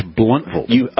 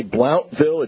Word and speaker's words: Blountville: It's Bluntville. – I was corrected quite strenuously by a judge and another defense attorney. You can't say Blountville, it's Bluntville, and you can't pronounce the T in county.